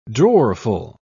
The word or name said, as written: drawerful